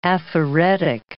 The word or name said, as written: aphaeretic